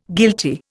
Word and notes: guilty: Indian

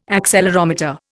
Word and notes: accelerometer: Indian

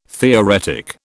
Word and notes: theoretic: British English

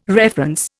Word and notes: reference: Indian

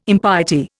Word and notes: impiety: Indian

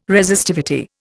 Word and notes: Resistivity: Indian

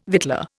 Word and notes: victualler: Indian

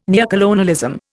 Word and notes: neocolonialism: Indian

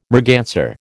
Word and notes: merganser: American English

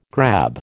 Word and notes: grab: American English

